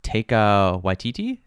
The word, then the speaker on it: Taika Waititi: Taika Waititi? Is that how he pronounces his name? – Oh, yeah.